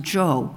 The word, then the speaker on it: Zhou: It's spelled Z-H-O-U, and from what I gathered, if YouTube is correct, it's pronounced Jo.